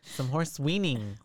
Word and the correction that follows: whinnying: What is it? – Whinnying. – Whinnying. – Whinnies. – Horse whinnies. – But whinnying is, I guess, how you would say it.